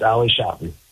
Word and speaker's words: Ali Shafi: Yeah, it's Ali Shafi, just like that.